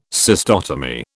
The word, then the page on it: cystotomy: British English